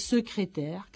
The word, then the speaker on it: secrétaire: Hay una palabra que es secrétaire, que tiene esas tres es.